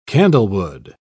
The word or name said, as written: candlewood